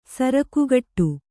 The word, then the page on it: ಸಾರ್ಕೆಗುಡು: sārkeguḍu